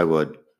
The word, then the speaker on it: would: Of course I would. Just ask. Así que eso no es lo más difícil del inglés, pero sí que tiene sus cosas. La pronunciación de would sin la L acordarte de usar el pasado simple para la parte hipotética.